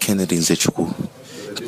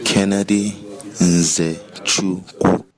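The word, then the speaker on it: Kennedy Nzechukwu: Well, I need to practice more between now and Saturday night, but I believe it is Kennedy and Zechiku. Let's hear how the man says it. – Kennedy and Zechiku. Kennedy and Zechiku.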